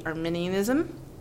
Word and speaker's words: Arminianism: which would be Arminianism. Did I say that right? – Sure.